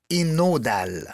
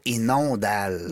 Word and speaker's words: Innodal: Président chez Laboratoire, si je le dis bien, Inodal. Exactement. Et non pas Inondal.